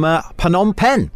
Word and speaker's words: Phnom Penh: We'll catch up with you again tomorrow with more from Phnom Penh.